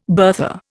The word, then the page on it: bertha: Indian